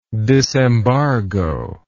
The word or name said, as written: disembargo